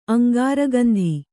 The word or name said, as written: ಅಂಗಾರಗಂಧಿ